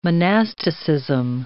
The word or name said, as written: monasticism